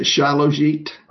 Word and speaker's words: Shilajit: Regarding minerals, is Shilajit a good supplement? S-H-I-L-A-J-I-T. – Yeah, I pronounce it Shilajit. – Okay, Shilajit, okay.